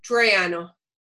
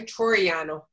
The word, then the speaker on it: Toriano: Toriano. Troy, like in King of Troy, Toriano.